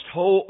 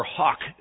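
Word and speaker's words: hough: And that word can either be pronounced "ho" or "hock" their horses and burn their chariots with fire.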